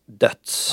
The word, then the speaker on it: Deutz: Huschampanjen här var av märket som stavas D-E-U-T-Z. – Ja. – Jag har lärt mig att det uttalas döds.